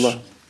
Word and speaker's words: Bhola: And I can't pronounce it. B-H-O-L-A. Bola, I guess. – Bola, yeah.